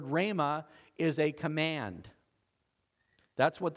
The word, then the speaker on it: rhema: It's spelled R-H-E-M-A, rhema.